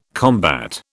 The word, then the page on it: combat: British English